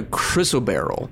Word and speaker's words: chrysoberyl: And then thumbing your way through, you also find a chrysoberyl.